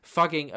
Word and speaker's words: Fugging: Fugging apparently better reflects the pronunciation of the town by locals.